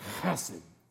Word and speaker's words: chesed: It's chesed. And it's pronounced just like that. You gotta, in order to say this properly, I know this is gross, but bear with me. You gotta say it like you're hawking one up.